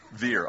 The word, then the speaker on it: Wir: P-F-L-U-G-E-N. Okay, there you go. So we're flugen. Is that how you would say that? Veer. Oh, yeah, that's right. I should know that. They don't pronounce their W's that way.